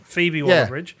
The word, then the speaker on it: Phoebe Waller-Bridge: Phoebe Waller-Bridge.